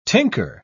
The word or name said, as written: tinker